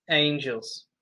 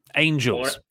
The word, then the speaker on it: Angels: After that, we got Alan Five Angles. – Angels. – Oh. Said it wrong a few times. Was I at least consistently wrong? – You said Angles. Every time. – Damn it. Angels.